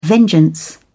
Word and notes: vengeance: British English

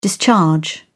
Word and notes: discharge: British English

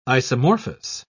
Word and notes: isomorphous: British English

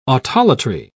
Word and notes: autolatry: British English

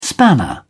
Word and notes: spanner: British English

